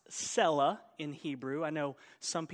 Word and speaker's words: Selah: So I want you to know why I pronounce it Selah, because it's pronounced Selah in the Hebrew.